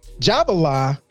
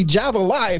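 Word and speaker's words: Java'la: So it'd be Javala if you pronounce it phonetically. Correctly, but black folks will call it Javala.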